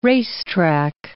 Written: racetrack

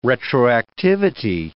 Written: retroactivity

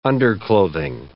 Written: underclothing

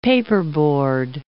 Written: paperboard

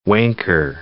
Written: wanker

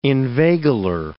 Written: inveigler

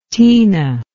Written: teena

